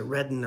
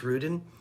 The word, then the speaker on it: Ruden: And I don't know if she pronounces it Redden or Rudin. I think it's Rudin. Sarah with an H on the end, R-U-D-E-N.